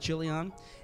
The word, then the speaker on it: Chilion: The man's name was Elimelech, his wife's name was Naomi, and the name of his 2 sons were Mahlon and Chilion. Some people pronounce that Chilion.